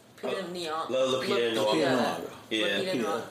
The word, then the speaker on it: Lupita Nyong'o: No, Lupita Nyong'o.